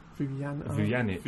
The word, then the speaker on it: Vujanic: Vujanic. – The J is a Y, like, you know, like Ljungberg.